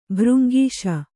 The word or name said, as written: ಭೃಂಗೀಶ